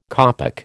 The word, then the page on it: kopek: American English